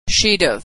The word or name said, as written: she'd have